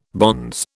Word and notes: bonze: British English

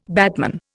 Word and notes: batman: Indian

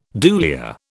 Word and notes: dulia: British English

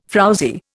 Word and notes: frowzy: Indian

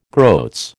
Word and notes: groats: American English

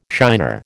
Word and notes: shiner: American English

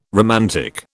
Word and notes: romantic: British English

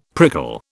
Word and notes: prickle: British English

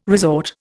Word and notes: resort: Indian

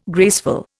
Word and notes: graceful: Indian

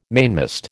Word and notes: mainmast: American English

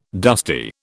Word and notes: dusty: British English

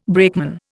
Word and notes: brakeman: Indian

